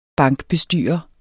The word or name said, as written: bankbestyrer